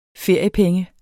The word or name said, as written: feriepenge